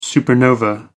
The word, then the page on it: supernova: American English